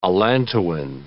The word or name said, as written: allantoin